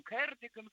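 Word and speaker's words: Cardigan: Ну, я думаю, что его фамилия, наверное, немножко по-другому, там, кардиган какой-нибудь. – Ну, он, кстати, кардиган был, да, потому что он британский, там ударение всегда на первый слог.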